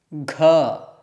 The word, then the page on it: gh: sanskrit